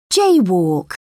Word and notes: jaywalk: British English

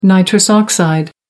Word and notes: nitrous oxide: American English

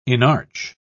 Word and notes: inarch: British English

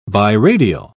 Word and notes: biradial: British English

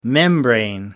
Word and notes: membrane: British English

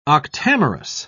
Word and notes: octamerous: British English

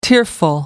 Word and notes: tearful: American English

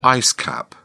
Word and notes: ice cap: British English

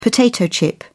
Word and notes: potato chip: British English

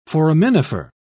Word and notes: foraminifer: British English

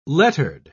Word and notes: lettered: British English

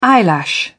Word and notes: eyelash: British English

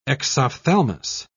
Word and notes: exophthalmos: British English

